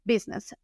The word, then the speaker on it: business: Entonces la palabra negocio se pronuncia biz, biz, y luego le metemos el nes, business, business, negocio.